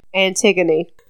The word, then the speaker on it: Antigone: I said it right, but I think I looked at it, and I was like, is this Antigone?